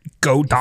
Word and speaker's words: Godot: but I was looking at Godot, I believe is how you say it. – Godot.